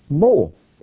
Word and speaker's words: mol: You see, the very word for circumcised is the word mul. You could spell it M-U-L. That word mul is found 36 times in the Old Testament in Scripture, in Hebrew. 36 times. But that exact same word, spelled exactly the same way, can also be pronounced mol. M-O-L.